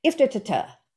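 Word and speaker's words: IFTTT: And then there are outside tools like there's one called Iftitatuh. And it's if this, then that. They don't actually pronounce it that way, but I do.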